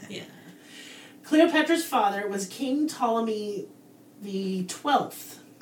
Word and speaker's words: Ptolemy: It's Ptolemy, but the P is silent because it sounds stupid to say Ptolemy.